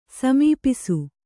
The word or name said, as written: ಸಮೀಪಿಸು